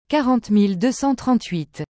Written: 40238

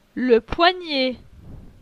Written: Le poignet

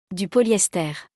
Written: Du polyester

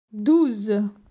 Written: 12

